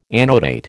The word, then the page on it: annotate: American English